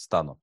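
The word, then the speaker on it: Stano: Официальный представитель Евросоюза Петер Стано, он словак, у них, по-моему, на первую гласное ударение, значит, Стану, заявил, что он допускает санкции против наблюдателей на референдумах из стран, внимание, Евросоюза, то есть из своих собственных.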